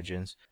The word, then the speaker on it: djinns: Yeah, I think that's how you pronounce it. I think the D is silent. – Yeah, djinns.